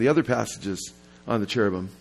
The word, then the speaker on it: cherubim: And we say cherub, we're talking about one, but we say cherubim, in the Hebrew, you pronounce like the im, I am. It's a plural. We have the S at the end of our words, they have I am.